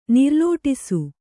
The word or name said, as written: ನಿರ್ಲೋಟಿಸು